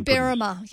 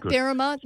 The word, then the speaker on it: Berrima: Is it Berrima? – Yep, oh, it's Berrima. Yep, you've got it right, Berrima.